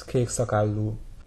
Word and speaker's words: kékszakállú: Or the long U is kékszakállú. – Here the static got really bad, but Henrik said that that Bluebeard's name, kékszakállú, isn't a very good example because the first syllable is always stressed, and because of that, the ends of words tend to be clipped off a little bit.